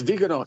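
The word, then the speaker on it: Viganò: It's Vigano, right? – Oh, yes, Vigano, yes.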